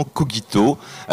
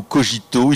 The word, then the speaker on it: cogito: Alors nous avons eu, pour les latinistes, une discussion pour savoir est-ce qu'on dit cogito ou cogito. Il semblerait qu'aujourd'hui, les deux prononciations soient acceptées, mais pour le principe, on va prendre cogito.